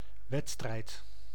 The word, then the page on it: wedstrijd: [ʋǝd.stɾɛjd]; /ˈʋɛt.strɛi̯t/